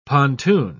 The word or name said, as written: pontoon